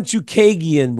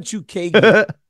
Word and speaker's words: Chookagian: Bro, you are bad with name pronunciation. – Chookigan. – Caitlin Chookagian, bro. – Well, there's no way you can know that. – I swear to God, Caitlin Chookagian.